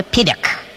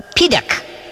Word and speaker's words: Piddock: I am a Piddock, and I am Uncle Bob's guest today. – Run that one past me again. Who did you say you were? – A Piddock.